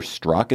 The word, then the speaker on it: Strzok: That is what FBI investigator Peter Strzok, is that how you say his name? – Yeah.